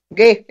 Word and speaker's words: Guex: ¿Es Wex o Guess? – Lo tendría que leer cómo se escribe. – G-U-E-X. – G-X. Ge. – Gay, exactamente. Entonces, Germain Gay.